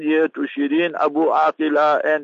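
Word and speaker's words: Abu Akleh: The Israeli Defense Force, last year, they murdered, premeditated murder it was, against the Al Jazeera journalist, Shiriz Abu Aqila, not Abu Eklein, all that. It is a kuffar mentality. We must learn to pronounce the words properly. Abu Aqila, and that was her father, and Shirin was her name.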